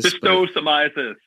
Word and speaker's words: schistosomiasis: But, you know, there are other things like, I'm not going to pronounce this right at all, but female genital schistosomiasis. – Schistosomiasis. – So, yeah. Schistosomiasis. I was close.